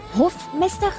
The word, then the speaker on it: Hofmeister: Jeroen, tu as souligné le pli de Hofmeister, c'est comme ça, c'est une bonne Hofmeister, prononciation. Excusez-moi.